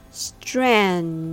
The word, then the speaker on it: strenuous: Now, when you say it all together, you get strenuous. Strenuous. Notice how the emphasis on the first syllable, so make sure that you put strenuous effort on that part.